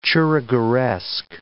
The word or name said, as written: churrigueresque